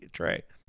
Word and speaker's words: Troyes: No. Different Troy. T-R-O-Y-E-S. Troyes? Trey? – Trey.